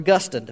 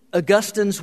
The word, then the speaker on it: Augustine: It's based primarily on the late 4th and early 5th century theologian Augustine, or Augustine, depending on how you want to pronounce it, and his work entitled The City of God. In Augustine's work, City of God, Augustine explained that the devil was defeated and he was bound at the cross.